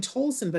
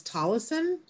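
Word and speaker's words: Tolleson: I've been saying Tolson, but it's Tollison.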